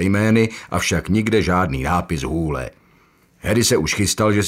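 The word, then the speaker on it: Hole: Připravil se na to, že zpočátku bude v Austrálii opravovat lidem výslovnost svého příjmení a vysvětlovat jim, že se čte Hůle, aby si ho nepletli s The Hall, tedy dírou.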